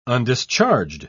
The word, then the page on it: undischarged: British English